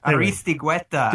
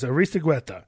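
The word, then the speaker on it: Aristeguieta: Agüesta? Aristigueta. – There it is. Aristigueta. Thank you.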